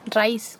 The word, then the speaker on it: RAIS: RAIS, se pronuncia como la raíz.